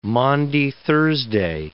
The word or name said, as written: Maundy Thursday